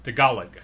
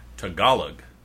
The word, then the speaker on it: Tagalog: I know how to say it. – Say it. – Tagalog. – Tagalog. And what little brothers do is tag along. – Tag along. – You drop an N into Tagalog and you get tag along.